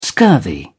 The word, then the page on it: scurvy: British English